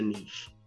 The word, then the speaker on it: niche: Third bucket is, depending on where you stay at, niche or niche. – Yeah, niche on the East Coast is niche. Yeah, but it don't rhyme with rich.